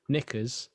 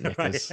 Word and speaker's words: Knickers: Oh, no, I've got three more good ones that I just added during the recording, because I'd forgotten about Silent K's. Knickers. I like Knickers.